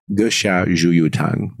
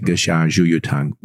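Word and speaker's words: Ge Xia Zhu Yu Tang (膈下逐瘀汤): It's called Ge Xia Zhu Yu Tang. That's how I pronounce it. Ge Xia Zhu Yu Tang, which translates to drive out blood stasis below the diaphragm formula.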